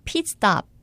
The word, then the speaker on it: pit stop: 피트 스톱, 정확한 영어 발음은 pit stop. pit stop인데요.